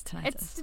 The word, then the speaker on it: tinnitus: Yes, tinnitus.